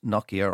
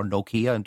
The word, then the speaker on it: Nokia: Now, you've mentioned Procter & Gamble and Nokia, or Nokia, depending on the pronunciation.